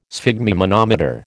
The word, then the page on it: sphygmomanometer: American English